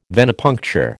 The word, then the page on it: Venepuncture: American English